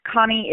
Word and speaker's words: Konni: Connie is K-O-N-N-I, Burton, B-U-R-T-O-N. I have a Facebook page, which is Connie Burton TX. Please follow me there. I'm also on Twitter, @ Connie Burton.